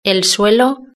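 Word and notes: el suelo: Spanish (Spain)

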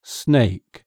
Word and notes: snake: British English

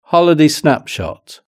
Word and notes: holiday snapshot: British English

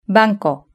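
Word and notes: banco: Spain

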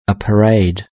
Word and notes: a parade: British English

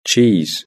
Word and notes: cheese: British English

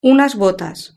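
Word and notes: unas botas: Spanish (Spain)

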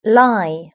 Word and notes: lie: British English

